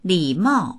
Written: li3mao4

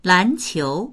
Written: lan2qiu2

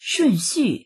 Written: shun4xu4

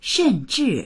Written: shen4zhi4

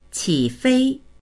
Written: qi3fei1

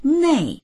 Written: nei4

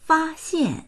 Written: fa1xian4

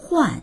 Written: huan4

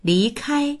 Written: li2kai1

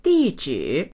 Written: di4zhi3

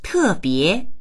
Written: te4bie2

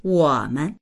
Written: wo3men

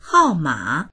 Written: hao4ma3

